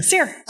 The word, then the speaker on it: CEER: Is that how you say it, CEER? – CEER. – CEER, okay. CEER is a lot easier to say.